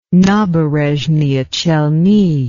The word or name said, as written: naberezhnye chelny